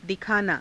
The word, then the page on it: dikhaanaa: hindi